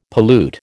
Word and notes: pollute: American English

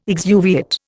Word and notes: exuviate: Indian

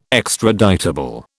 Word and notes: extraditable: British English

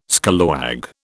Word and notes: scallawag: British English

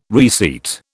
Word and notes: reseat: British English

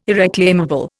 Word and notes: irreclaimable: Indian

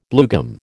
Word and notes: bluegum: American English

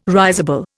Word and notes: risible: Indian

